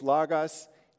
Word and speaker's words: logos: I was actually having a discussion with Keith Elder last week about, you know, pronouncing the O's long or short. I believe technically it's logos, but some people, you know, argue it could have been logos.